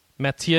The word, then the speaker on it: Mathieu: In the chat room, Mathieu, how should I say that? That's Frenchy. – Mathieu.